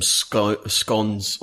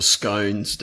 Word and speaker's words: scones: I may have taken some scones or scones, depending on how you want to pronounce it,